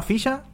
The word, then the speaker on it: Fixa: Ya, se llama Ficha, ¿Ficha? Pero se escribe F-I-X-A.